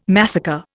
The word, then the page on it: massacre: Indian